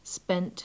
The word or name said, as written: spent